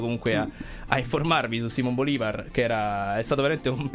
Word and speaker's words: Bolívar: Che non è Simón Bolívar. – Non è Bolívar, no. Ma Bolívar, proprio Simón con l'accento Bolívar. Sulla I.